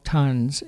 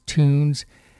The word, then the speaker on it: TUNS: The group is called Tons. If you're from Nova Scotia, and particularly the Halifax area, you'll recognize that as being the Technical University of Nova Scotia, but also TUNS. You could mispronounce it as Tunes.